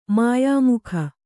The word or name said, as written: ಮಾಯಾಮುಖ